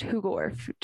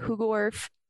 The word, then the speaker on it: Hoogerwerf: My last name is pronounced Hoogerwerf, just in case you want to know. Bekah Hoogerwerf. – Hoogerwerf? – Hoogerwerf. It is phonetic.